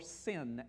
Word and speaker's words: sin: and it'll say shin or and or sin, S-I-N, which is another Hebrew way of pronouncing that letter.